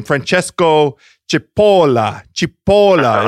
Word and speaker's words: Francesco Cipolla: And he gave me the proper way to pronounce an Italian. If we wanted to call him by his Italian name, Francesco Cipolla is nice.